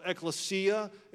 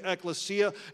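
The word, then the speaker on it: ekklesia (ἐκκλησία): It's called ekklesia. It's spelled pretty much just as I'm pronouncing it, ekklesia, and it means called out ones, and the church is called out.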